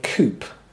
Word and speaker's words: coupe: Like, you know how there are different sorts of sports cars, and one of them we call a coupe. Americans pronounce that word coupe.